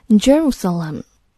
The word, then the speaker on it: Jerusalem: 让我们来看最后一句话：In Jerusalem, the Western Wall, the holy site where Jews are permitted to pray, is largely deserted. Rabbis have urged the faithful not to kiss the stones. Jerusalem 就是非常著名的耶路撒冷，我们要熟悉它的英文读音 Jerusalem。